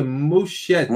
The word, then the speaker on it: Muschietti: Andy, como é que se pronuncia? Muschietti. Muschietti. Muschietti, é.